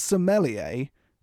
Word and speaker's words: sommelier: It's sommelier. It does, it's not got an A in it. It's spelled S-O-M-M-E-L-I-E-R, right? Sommelier, not sommelier. It's not, he's not from Somalia.